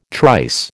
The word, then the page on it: trice: American English